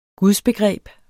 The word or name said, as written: gudsbegreb